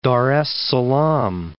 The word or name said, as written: Dar es Salaam